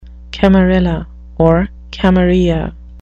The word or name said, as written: camarilla